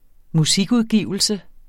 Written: MUSIKUDGIVELSE

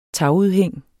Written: tagudhæng

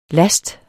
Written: last

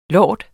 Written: lord

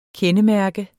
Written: kendemærke

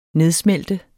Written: nedsmelte

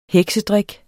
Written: heksedrik

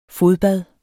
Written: fodbad

